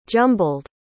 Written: jumbled

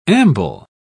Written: amble

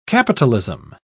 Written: capitalism

